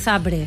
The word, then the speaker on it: Zabrze: sabes que es Zabrze? – ¿Por qué pronuncias Zabrze? – Porque es así.